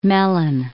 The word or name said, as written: Mellon